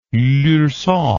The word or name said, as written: Lurcat